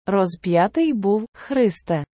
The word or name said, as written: розп'ятий був, Христе